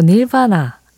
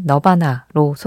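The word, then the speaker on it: Nirvana: 뭐, 닐바나, 라고 발음을 하는 게 좀 더 정확하겠지만, 뭐, 우리끼리는 그냥 우리가 잘 알고 있는 너바나로 소개를 할게요.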